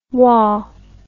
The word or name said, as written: wah